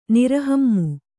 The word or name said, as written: ನಿರಹಮ್ಮು